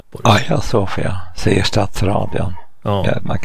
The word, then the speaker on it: Hagia Sofia: Frågan är varför man skriver Hagia Sofia. Aya Sofia är det grekiska uttalet. Men av någon orsak så translitterar man med H-A-G.